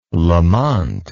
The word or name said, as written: lamont